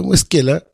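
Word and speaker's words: Squella: Porque como dice Agustín Esquela, ¿cómo se dice Esquela o Escuella? Esquela, que es un gran intelectual del puerto, al puerto hay que merecerlo.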